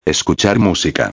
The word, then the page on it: escuchar musica: espanhol